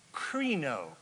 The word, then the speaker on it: krino: krino, K-R-I-N-O. Krino, which rhymes with Reno, and that's, I don't know what that means.